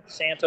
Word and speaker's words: Sant: He designed, built, installed, and provided all aquacultured corals and marine fish for the Indo-Pacific Coral Reef Aquarium located in the Sant, is that how you pronounce it? Sant? – Yeah, Sant Ocean Hall.